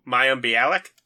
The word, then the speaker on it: Mayim Bialik: I can never remember how to pronounce her name. Mayim Balik? Is that it? Mayim. Oh, Mayim Bialik? – Just yeah.